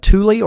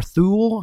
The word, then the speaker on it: Thule: I use Thule or Thule, however you want to pronounce it, T-H-U-L-E, Thule Atmos cases.